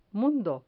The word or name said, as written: mundo